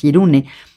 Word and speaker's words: hirune: El título es una palabra japonesa que es hirune, supongo que se pronuncia hirune, H-I-R-U-N-E.